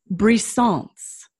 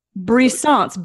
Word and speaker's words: brisance: Brisance, B like boy, R-I-S like Sam, A-N-C-E. Of course, I'm sort of pronouncing it in the Frenchy way, brisance, but it's probably brisance, right?